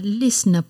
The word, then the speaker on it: lyssna: Jag gillar att lyssna på musik.